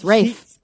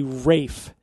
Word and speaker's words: Ralph Fiennes: His name is spelled R-A-L-P-H, right? – That's Ralph. – But it's Rafe. – But it's pronounced in the old English way, Rafe. – Yeah, according to who, though? – Yeah, according to him. – According to him. Although he said he kind of gave up correcting people because it's a lost cause, because people are pronouncing it the way it's spelled.